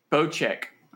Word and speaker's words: Bocek: We also got a new weapon called the Bokek. I don't know if I'm saying that right or not. – I doubt it. – Bocek, because it says pronounced Bocek, but I didn't, I didn't read that part.